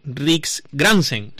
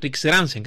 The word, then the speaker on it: Riksgränsen: Y nos vamos a ir hasta el norte de Europa, hasta el norte de Suecia, lo diré bien, ¿eh? Riksgränsen, Riksgränsen, creo que se pronuncia así.